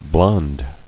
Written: Blondness

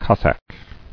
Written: Cossack

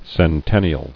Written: Centennially

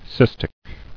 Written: Cystic

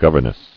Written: Governess

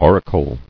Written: Auricle

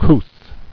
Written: Couth

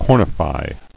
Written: Cornified

